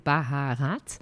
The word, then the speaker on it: baharat: You write it baharat, but you say baharat or baharat, you know, with the... – With the guttural, yeah.